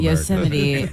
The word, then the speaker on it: Yosemite: Yosemite. How we pronounce it here. That's the way good Americans pronounce it. – Okay. Tell Americans. As a bad American at Yosemite, there was the guy.